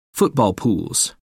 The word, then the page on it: football pools: American English